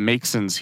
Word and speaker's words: Maxon: The city, Long Lake Township, is arguing that the Maxons here, it's spelled M-A-X-O-N, so I guess I mispronounced it the first time. Go with Maxon.